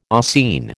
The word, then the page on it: ossein: American English